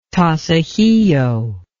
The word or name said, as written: tasajillo